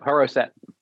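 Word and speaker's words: Haroset: It's super, super simple, but it's, is it Harasat? How do you pronounce mm-hmm. it? Yep, Harasat.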